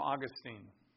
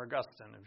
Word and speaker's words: Augustine: They got the name minor from Augustine, or Augustine, if you want to pronounce his name that way.